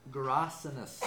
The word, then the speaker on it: Gerasenes: So it's after they calms the storm, they finished going to the other side of the lake to the region of Gerasenes.